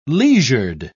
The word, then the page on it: leisured: British English